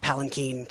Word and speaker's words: palanquin: By the way, I make this pun because it's actually pronounced palanquin, turns out.